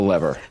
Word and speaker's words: Lever: How do you pronounce the name of the outfit? – It depends where you are. We call it The Lever.